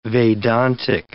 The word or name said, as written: Vedantic